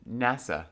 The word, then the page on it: NASA: American English